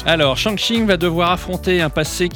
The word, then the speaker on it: Shang-Chi: La première sortie de la semaine, c'est Shang-Chi et la légende des 10 anneaux. J'espère que je le prononce bien. – C'est tout à fait ça.